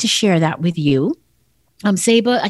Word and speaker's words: Saba: A lot of people want to pronounce it Saba, but it's called Saba.